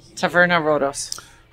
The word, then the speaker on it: Taverna Rodos: Taverna Rodos.